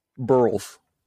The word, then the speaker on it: Burles: Burles. Like saying barrels without the A.